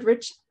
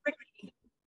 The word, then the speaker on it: Ricci: That's how you pronounce it. Ricci. – And, oh, is it Ricky? I've always said Ricci. – It's Ricky. I did too.